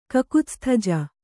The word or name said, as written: ಕಕುತ್ಸ್ಥಜ